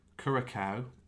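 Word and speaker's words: Curaçao: Curacao and Sint Maarten.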